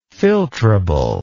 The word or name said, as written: filtrability